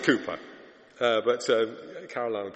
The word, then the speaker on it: Cowper: Now, I call him William Cooper. It's spelt C-O-W-P-E-R, but I call him Cooper.